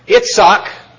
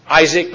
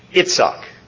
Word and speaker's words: Itzhak: Itzhak, it's not pronounced Isaac by anybody but Americans and British. Everybody else says it. Itzhak, technically. The I is always an E.